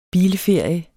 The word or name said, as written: bilferie